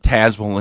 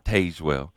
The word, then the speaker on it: Tazewell: And thank goodness you said Tazewell and not Tazewell.